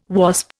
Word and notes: Wasp: Indian

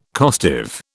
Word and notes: costive: British English

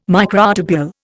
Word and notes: microtubule: Indian

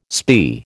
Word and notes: spae: American English

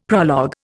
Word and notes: prologue: Indian

